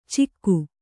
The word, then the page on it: ಚಿಕ್ಕು: Kannada